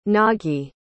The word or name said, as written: nagi